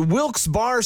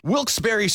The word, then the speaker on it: Wilkes-Barre: And you're listening to Wilkes Barre Scranton's 97.9X. Now, I had heard that it was supposed to be pronounced Wilkes-Barre. Wilkes-Barre Scranton's 97.9X. The only way to pronounce Wilkes-Barre.